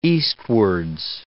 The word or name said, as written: eastwards